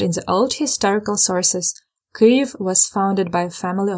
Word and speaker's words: Kyiv: In Ukraine, in Ukrainian, we say Kyiv, and we prefer to spell it in Latin as K Y I V, Kyiv.